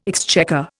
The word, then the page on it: exchequer: Indian